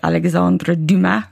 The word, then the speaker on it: Alexandre Dumas: And that book was The Three Musketeers by Alexandre Dumas.